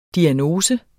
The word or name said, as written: diagnose